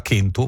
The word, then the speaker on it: kentu: Pensate per esempio che nel sardo rimane una pronuncia arcaica della C davanti a vocale, per esempio la parola cento, che in latino veniva pronunciata centum, noi la pronunciamo cento come quasi tutte le lingue neolatine, tranne il sardo che la pronuncia ancora kentu, uno delle lingue della Sardegna la pronuncia kentu, kelu, eccetera eccetera,